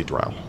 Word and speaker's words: drow: I commonly say drow. I know it is technically pronounced drow. – I hate the way that sounds. – I know. – Yeah, it doesn't sound good. – I'm the same way. I grew up saying drow, and I'm not going to be able to break that. – But in official Paizo, it is pronounced drow. – But I don't like that. – Because I remember they had a small header section that just said... Rhymes with bow.